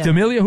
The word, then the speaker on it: D'Amelio: Dixie D'Amelio. Is that, am I saying that right? Yeah, but 25. Is that an actress? – D'Amelio.